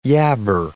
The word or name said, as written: yabber